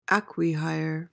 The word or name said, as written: acquihire